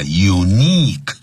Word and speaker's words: unique: 好，任何一个字结尾是有 Q-U-E 的时候啊，特别记住呢，前面的 I 一定要念成 E，长元音，叫 unique boutique，那都是从法语过来的啊。